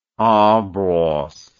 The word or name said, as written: en brosse